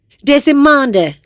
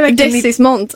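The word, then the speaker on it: Deasismont: Du vet väl hur man uttalar mitt efternamn? – Desimonde. – Desismont.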